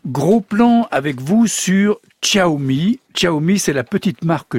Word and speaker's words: Xiaomi: Et oui Pierre, et comme vous l'avez bien prononcé, Xiaomi, les Français découvrent actuellement cette marque et ses smartphones au rapport qualité-prix imbattable.